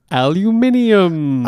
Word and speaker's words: aluminium: We got 13 is the atomic number for aluminium.